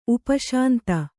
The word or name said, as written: ಉಪಶಾಂತಿ